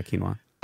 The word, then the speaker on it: quinoa: quinoa, am I saying it right? – Yeah, quinoa.